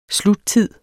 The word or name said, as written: sluttid